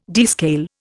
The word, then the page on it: descale: Indian